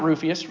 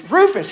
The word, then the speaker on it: Rufus: Greet Rufus. Not Rufius. Rufus. He would have been really upset at me for mispronouncing his name.